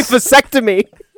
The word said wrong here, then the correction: Fessenden: Here, let me spell it. It's F-E-S-S-E-N-D-E-E-N. – Fessenden. – Fessenden. – Fessenden. – Larry Fessenden. – Trying to pronounce every single letter in the guy's name. – Pronunciation is my thing. Like, I can't skip a letter. I can't blur them.